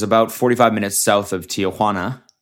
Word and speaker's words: Tijuana: Like I pronounce it. I think I pronounce it correctly there, like a Mexican would. Tijuana. It seems to be how they, you know what? Fuck it. I'll just say it the white way.